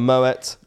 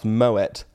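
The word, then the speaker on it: Moët: Bottle of Moet, which by the way, for anyone out there listening who may be unaware, it is pronounced Moet. I've had confirmation from someone at Moet. I always thought it was Moe.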